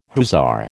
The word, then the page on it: hussar: American English